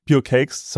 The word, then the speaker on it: Pure Cakes: Und jetzt interessiert uns natürlich genau, äh, was verbirgt sich hinter äh, Pure Cake? Habe ich das richtig ausgesprochen? – Pure ja, Cake. Pure Cakes, sorry.